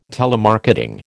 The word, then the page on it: telemarketing: American English